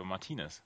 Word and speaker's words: Martinez: auf der zweiten Silbe, Martinez.